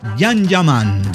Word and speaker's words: Can Yaman: Se pronuncia Jan Yaman.